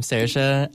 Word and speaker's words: Saoirse: Yeah, so that's how you say that name, Saoirse. I always see it, and I'm like, I don't know how to say it. – I'm not sure how to say that. – Is it like... S-A-O-R-S-E. – Yeah, like Saoirse Ronan. Yes, it's exactly like that.